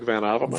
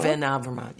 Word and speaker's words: Van Avermaet: Like BMC has, I can never say his name. Greg Van Avermaet. – Van Avermaet, yes.